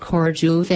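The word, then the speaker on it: Corjuem: Our third inland fort of Goa is at Corduum, pronounced Corduve, an island in Bardez surrounded by the Mapusa River.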